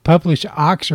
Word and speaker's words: Publish0x: But we're going to talk about, is it Publish0x or Publish0x? Which is the proper way to say it? – Yeah, it's pronounced Publish. They just call it Publish.